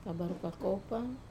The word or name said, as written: kavaru kakopa